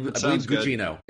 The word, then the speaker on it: Gugino: I believe Gugino is how we say her name.